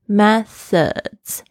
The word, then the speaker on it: methods: 方式 methods，这个 th 的发音要轻轻咬住舌尖，发出这个的声音。 methods，还有后面这个 ds 的声音呢，有很多同学有困难念哦。其实它有一点像是我们孩子的子那个声音 z z。 methods have been successful for you。